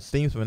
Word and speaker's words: Steemsma: And when I asked him how to pronounce his name, he said Steemsma, and I was like, wasn't it Steemsma last year?